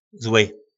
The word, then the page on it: zly: po nasimu